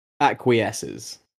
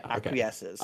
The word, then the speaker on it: acquiesces: A-C-Q. – Yep. – U-I-E-S-C-E-S. – Acquiesces. Yeah, acquiesces. – Okay. I don't even know what the fuck it means. Let's have a Google, but that's how you say it.